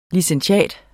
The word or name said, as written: licentiat